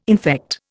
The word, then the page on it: infect: Indian